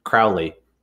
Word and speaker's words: Crowley: And then with Crowley, whose name I intentionally mispronounce, people are always saying like, oh, he doesn't say Crowley. He doesn't actually know Aleister Crowley. I say, no, I pronounce it like David Bowie because I discovered him through David Bowie.